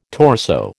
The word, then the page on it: torso: American English